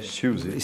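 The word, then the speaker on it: Siouxsie: Susie? – Susie, sì.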